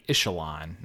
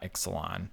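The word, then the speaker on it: Ixalan: also, one of our readers felt compelled to tell us that he believes if it is pronounced in the Aztec tongue, not tongue, but pronunciation guide, it would be Ishalon, not Ixalon. – Oh, the X is an SH there.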